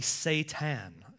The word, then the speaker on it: Satan: as well as this one, Satan, which is actually Satan, is the way it's actually pronounced in the original language.